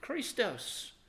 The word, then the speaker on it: Christos: In Greek, though, it's Christ. They would pronounce it Christos.